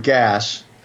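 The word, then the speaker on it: gaz: The word gas, which is pronounced, you pronounce the S, unlike some words that end in S in French.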